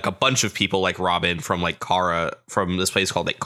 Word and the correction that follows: Ohara: Ohara is how it's pronounced. – Okay. Oh, I saw it as a C, not an O. Okay. – Yeah, it's Ohara.